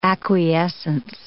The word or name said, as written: acquiescence